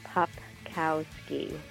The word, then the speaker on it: Popkowski: Well, it's funny because it's very phonetic. It's Popkowski.